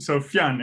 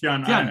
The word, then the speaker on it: Fionn: So Fionn. It is Fionn. I sometimes if people have trouble with that, I say you can just like Finn, like Huckleberry Finn is also okay.